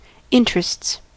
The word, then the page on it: interests: American English